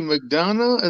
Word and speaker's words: McDonough: Not McDougal. Not McDougal. – JD McDonough? Is that how you say it? – McDonough, yeah. – It. Yeah, I've always said McDonough. It's but if McDonough. It isn't McDougal, she's saying McDougal.